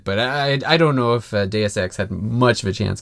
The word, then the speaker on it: Deus Ex: And in the second matchup, we had Bravely Default versus Deus Ex.